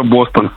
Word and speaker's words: Бостон: Кстати говоря, мы не знаем точное ударение, Бостон или Бастон. Доброе утро, уточните. – Доброе утро, Бостон.